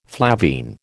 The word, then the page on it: flavine: American English